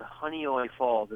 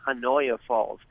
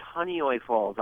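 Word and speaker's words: Honeoye Falls: Yeah, I was born and raised in a little town called Hunnioi Falls. It's hard to pronounce. It looks like Hanoia Falls, but it's Hunnioi Falls.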